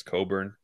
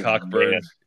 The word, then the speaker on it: Cockburn: I like to say Cockburn. Yeah. – I think they say Coburn just because they don't want to. They know they'd giggle or something.